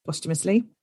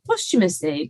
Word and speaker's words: posthumously: Yeah, you have. Posthumously.